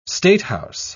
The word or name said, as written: statehouse